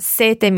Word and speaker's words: sete: It's very similar, but number seven is double T, sete. And sete meaning thirst is single T. So when you say thirst, you can have a very long E, sete. While when you say number seven, sete, you need to be very short on your E to allow a very long T sound, sette.